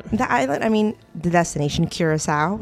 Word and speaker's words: Curaçao: Curaçao. – Curaçao. – The C has a cedilla under it, and that makes you pronounce the C as a S. That's why we say Curaçao.